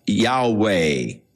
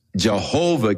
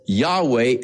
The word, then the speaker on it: Yahweh: So the general consensus of opinion is that the name was to be pronounced Yahweh. But somewhere along the line, the pronunciation of Jehovah came along, or Jehovah, and it has become more popular, but Yahweh is probably the correct pronunciation, though we do not know for sure.